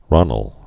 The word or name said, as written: ronnel